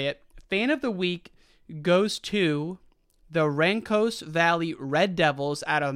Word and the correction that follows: Rancocas: And I'm now reading that Rancos is pronounced Rancocas.